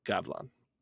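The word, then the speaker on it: Gavlan: And the best, just like you said, best part about it, one man, 2 syllables, Gavilon.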